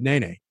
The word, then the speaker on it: Nene: Tell me how you say your name. And he just said, Nene.